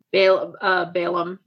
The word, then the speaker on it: Balaam: If we go to, how do you say this in English? If we go to Bil'am. – Balaam and his donkey.